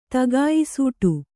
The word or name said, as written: ತಗಾಯಿಸೂಟು